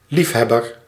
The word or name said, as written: amateur